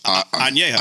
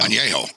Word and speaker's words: añejo: You've got this, how's it pronounced? – Añejo. – Añejo. – Yeah.